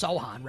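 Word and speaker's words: Sochan: How do we pronounce Sohan, right? – Yeah. – Point Sohan.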